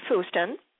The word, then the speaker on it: Fustin: And Gino Goss and Gary Fustin, rhymes with Houston, will be on the show, this show, Hybrid Jazz from Trish Hennessy, next Tuesday.